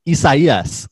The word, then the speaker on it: Isaias: Yeah, well, it's Hurricane Isaias. – That's for doing that right.